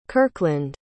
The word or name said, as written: kirkland